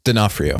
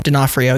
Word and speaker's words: D'Onofrio: I think, how do you say his last name? Vincent? – D'Onofrio. – D'Onofrio, yes. I think he definitely like takes that role and he fits it perfectly.